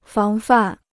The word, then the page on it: 防范: fáng fàn